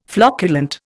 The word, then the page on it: flocculent: Indian